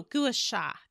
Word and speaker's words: gua sha: I am saying it right, gua shu. Yeah. I mean, like you can go get a massage, but you can do it to yourself. But that is no gua sha, gua sha.